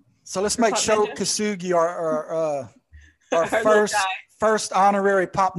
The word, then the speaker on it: Shou: How do you say his name? Shou Kosugi? – Yeah. S-H-O. Shou. – Okay. – Kosugi. – Maybe that's our S-G-G-I.